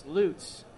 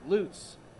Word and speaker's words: Lutz: Yes, Lutz is how you pronounce Danny Bautista Jr.'s hometown. Lutz.